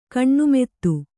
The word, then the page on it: ಕಣ್ಣುಮೆತ್ತು: kaṇṇumettu